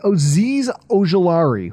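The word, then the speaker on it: Azeez Ojulari: Aziz Ojulari, who I always butcher his name. I'm surprised I got it right that time.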